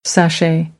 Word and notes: sashay: American English

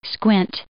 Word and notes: squint: verb

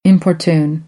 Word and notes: importune: American English; t

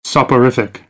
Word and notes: soporific: American English

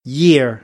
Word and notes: year: American English

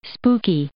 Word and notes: spooky: adjective

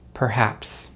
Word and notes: perhaps: American English

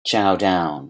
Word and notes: chow down: phrasal verb

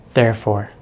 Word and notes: therefore: American English

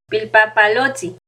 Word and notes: Pilpapalotzin: Náhuatl

